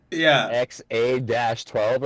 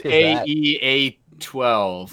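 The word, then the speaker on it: X Æ A-12: Didn't he name it like fucking XA-12 or something? – Yeah, it's AEA-12. – Yeah, but it's pronounced X-A-12.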